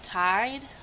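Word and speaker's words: Tiede: So we're gonna be talking about Kay and Rolf Tide. I think that's how you pronounce it.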